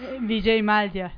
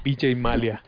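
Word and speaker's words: Vijay Mallya: Vijay Malia. – Vijay Malia.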